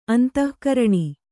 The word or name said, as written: ಅಂತಃಕರಣ